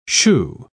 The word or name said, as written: chou